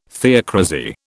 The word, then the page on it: theocrasy: British English